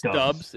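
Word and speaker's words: Doubs: I have no clue how to pronounce Dubs. – It's Dubs. It's Romeo Dubs.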